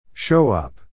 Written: show up

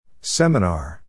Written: seminar

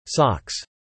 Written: socks

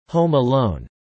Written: Home Alone

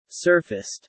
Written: surfaced

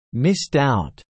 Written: missed out

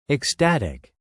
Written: ecstatic

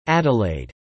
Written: Adelaide

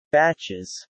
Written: batches

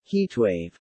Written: heatwave

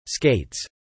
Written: skates

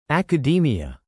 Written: academia

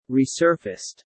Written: resurfaced

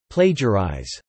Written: plagiarize

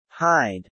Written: hide

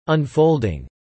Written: unfolding

